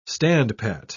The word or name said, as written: standpat